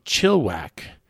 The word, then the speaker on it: Chilliwack: He says, hey, it is I, Kevin from Chilliwack, pronounced chill, short like an ick, and then whack. So there's the second I is silent, which...